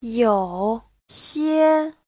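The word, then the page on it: youxie: you3xie1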